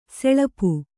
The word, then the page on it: ಸಲಪು: salapu